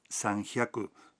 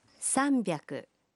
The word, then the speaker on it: sanbyaku: 300 no se pronuncia sanhyaku, sino sanbyaku.